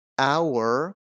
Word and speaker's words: hour: یا اینجا کلمه hour به معنی ساعت، یک ساعت، hour. خب اولین صدایی که میشنوم ا هست، بنابراین میگم an hour.